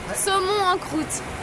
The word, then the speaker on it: saumon en croûte: Saumon en croûte.